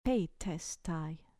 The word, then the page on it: petestai: grec